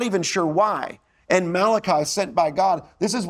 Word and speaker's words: Malachi: actually Malachi is how you pronounce it, Malachi, in Malachi 3, he's explaining why the people were not experiencing God's blessing.